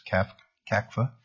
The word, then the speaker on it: CACFA: I'd like to always establish first that the pronunciation that we're using in the Senate is CACFA. There's other pronunciations. We'll standardize on that.